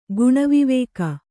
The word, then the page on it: ಗುಣವಿವೇಕ: guṇa vivēka